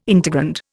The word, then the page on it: integrand: Indian